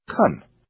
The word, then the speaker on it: can: Also, since can is not stressed, the vowel is reduced to a, so can is actually pronounced can.